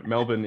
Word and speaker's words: Melbourne: Melbourne is definitely the way that we pronounce it, or Melbs, or Yeah, Melbs, I think some people call it here, but Melbourne is we just don't use R at all unless it's at the very, very start of a word or halfway in between.